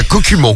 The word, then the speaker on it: Cocumont: Ça s'écrit Cocu comme Cocu. – C'est ça, Cocumont.